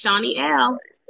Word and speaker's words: Shani L.: Yes, yes, yes, Shani L.